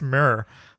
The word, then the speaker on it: Muir: Do you know how M-U-I-R? Is that Muir? – I think it's Myrrh. – Myrrh? – Yeah, like frankincense? – No, myrrh?